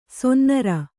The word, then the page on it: ಸೊನ್ನಾರ: sonnāra